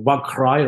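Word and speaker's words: WAG/Rij: which is the Wagkrai rats. That's the name, the correct pronunciation in the Netherlands.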